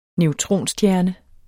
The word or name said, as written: neutronstjerne